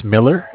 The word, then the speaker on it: Miller: Yes, spelled Z-A-C-H. – And your last name? – Is Miller, M-I-L-L-E-R.